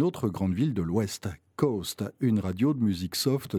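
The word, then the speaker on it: KOST: Le reste du temps, Coast, K-O-S-T, K-O-S-T pour le call letters, mais prononcé phonétiquement Coast comme la côte, sous-entendu West.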